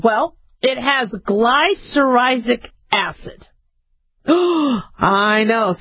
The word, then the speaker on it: glycyrrhizic: It's G-L-Y, so there's your gly, and then it's C-Y-R-R-H. So, cyrrhizic, I-Z-I-C. So, I'm saying it's glycyrrhizic acid.